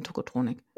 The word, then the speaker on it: Tocotronic: Tokotronik spricht man das aus? – Ja, eigentlich es gab ja damals dieses äh, Lied, äh, oder This Beat is Technotronic. Ja. Und die nannten sich dann Tokotronik. Und das ist halt wirklich deutsch. – Aber die, aber die haben doch auch ein Lied, das heißt This Boy is Tokotronic. – Ja, weil wenn es Englisch ist, dann spricht man es halt Englisch aus. Aber eigentlich heißt sie Tokotronik.